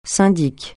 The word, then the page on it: syndic: francais